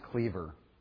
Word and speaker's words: Kliewer: Nothing has changed there, but one that we I know we had on here at one time, her sister-in-law, Charlotte Cleaver, and it's spelled K-L-I-E-W-E-R. Sorry? Well, it's pronounced Cleaver.